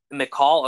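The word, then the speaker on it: Mikal: Michael, or is it me? – McCall Bridges. – McCall, okay. That's I can never pronounce his name right. McCall Bridges.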